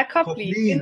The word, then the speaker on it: Kolping: Wie wird dein Name richtig ausgesprochen? Kolping, oder? – Ja, Kolping. Genau.